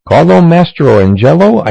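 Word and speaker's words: Carlo Mastrangelo: Here is one of the more difficult names in old-time rock and roll just to pronounce. That's why they always called him Carlo and the Belmonts. His name? Carlo Mastroangelo. I said it right.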